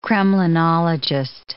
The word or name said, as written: kremlinologist